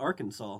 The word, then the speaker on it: Arkansas: I can teach with my PA cert, but not Arkansas. Like, what makes them so good? They don't even know how to pronounce their state. It's Arkansas.